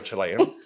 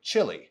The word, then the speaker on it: Chile: And I know, like black pepper is considered spicy to a Chilean. So I agree. But I've always found it ironic that in the United States, they don't pronounce this country Chile. They say chili.